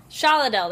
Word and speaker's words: Shaladel: And Icky Spiderwebs and Shadell. Did I say it wrong? I, like, wrote it. – Shaladel. – Shaladel. There we go. Extra L.